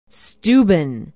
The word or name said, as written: steuben